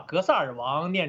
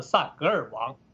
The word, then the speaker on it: 格萨尔王: 先讲一个轻松一点的话题，就是他这一次把他兜书袋卖弄学识的这篇讲话里边又出了一个错误，就是在原来的重伤宽衣的那一次之后，这一次呢又把格萨尔王念成了萨格尔王。